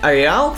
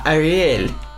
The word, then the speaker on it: Arial: что правильно говорить не Ареал, как здесь чувак пишет, а Ариэль.